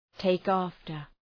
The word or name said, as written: take after